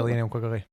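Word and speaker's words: Linnea Quigley: How do we say her name? – Oh. – Leanna Quigley. – Leanna Quigley? – Yeah, Leanna Quigley.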